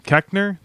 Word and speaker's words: Koechner: Keckner, that's how you say it.